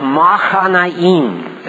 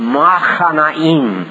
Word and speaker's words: Mahanaim: Why would we gaze on you, on the Shulamite, as on the dance of Mahanaim, is how it's pronounced. Mahanaim.